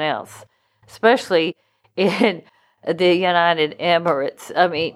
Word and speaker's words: Emirates: Some people pronounce it Emiratis, but I say Emirates. I hope that's right.